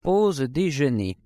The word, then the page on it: pause dejeuner: French